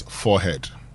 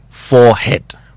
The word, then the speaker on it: forehead: Now, I'm bringing this because somebody asked me whether it is forehead or forehead. Now, the Americans pronounce it as forehead. – Forehead. – The British accept forehead and forehead. So, if you say forehead, you're right. If you say forehead, you're right. Okay.